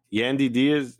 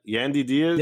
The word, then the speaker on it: Yandy Diaz: Yandy Diaz, exactly. Yeah. Yandy Diaz.